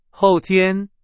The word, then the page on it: 后天: Chinese